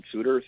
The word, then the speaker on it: Souter: Done a couple documentaries and a few short films, which you can find online if you just look up my name, Colin Suter, C-O-L-L-I-N. Suter is spelled S-O-U-T-E-R, and you can find some of my short films and documentaries online.